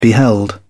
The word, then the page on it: beheld: British English